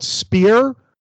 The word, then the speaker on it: Speir: There's a winery called Speer, but it's spelled like S-P-E-I-R.